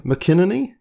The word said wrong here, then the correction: McInerney: Or is that right? Go on, correct me again. – Tim McInerney. Yeah.